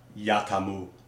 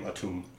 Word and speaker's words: Atum: Like, real quick, I'm looking at the phonographic pronunciation of it, and it's actually pronounced Yatamu. – Okay. – Yatamu, but then it later became abbreviated to just Yatum, and then that somehow became Atum.